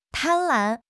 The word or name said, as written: 贪婪